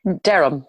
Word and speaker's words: Derham: Derham, yeah.